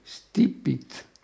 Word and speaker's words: stipit: per cui erano anche dette più italianizzando stipit, una voce che si pronuncia sibilando in stipit.